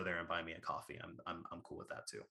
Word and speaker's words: Ko-fi: I think it's pronounced coffee, but I always say Ko-Fi so that people like spell it correctly.